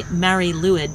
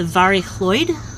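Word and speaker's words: Mari Lwyd: and it's the Mary Lewid, but it's actually pronounced the Vari Chloid.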